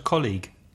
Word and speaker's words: colleague: And another E-A word, colleague. Colleague. And also the problem of the G-U-E at the end. The word is spelled C-O-L-L-E-A-G-U-E, the pronunciation colleague.